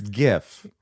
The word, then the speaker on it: GIF: anyway, there's, is it GIF? – It's GIF. – Okay, but some people are like, oh, it's actually pronounced with a J, even though it's a G.